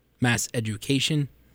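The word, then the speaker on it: MassEducation: And this is called Mass Education. Am I saying that right? – That's correct.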